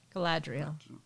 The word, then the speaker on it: Galadriel: Galadriel. – Galadriel.